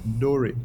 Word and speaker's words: Dhoray: Dhoray mi fa sol la ti do. – Oh yeah, I would not do that, but I would think it. Yeah. – So that's exactly how you pronounce it, Dhoray.